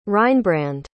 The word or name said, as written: reinbrand